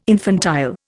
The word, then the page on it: infantile: Indian